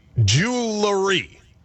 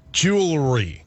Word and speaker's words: jewelry: And they say that you are supposed to say jewelry. Like, you're supposed to put in that syllable. So, jewelry. – Jewelry.